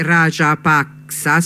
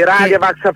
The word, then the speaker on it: Rajapaksa: Stiamo parlando, di Rajapaksa, si pronuncia così, Sì, Rajapaksa Famiglia.